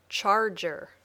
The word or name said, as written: charger